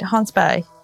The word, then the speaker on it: Hans Berg: I'm, i might be pronouncing his name incorrectly because uh, it's pronounced differently in Swedish, isn't it? – No, you said it right, Hans Berg.